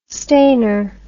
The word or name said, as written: stainer